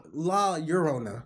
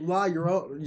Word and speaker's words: La Llorona: It's pronounced like with a Y. It's like yo yo. La Llorona. There you go. Yes. yes. But uh, roll your R when you do it. La Llorona.